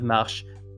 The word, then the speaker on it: marche: And why, for instance, a common one I get is why the word marche, M-A-R-C-H-E, is pronounced marche, but the word marcher, M-A-R-C-H-E with an accent aigu, is pronounced marché.